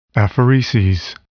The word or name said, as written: apheresis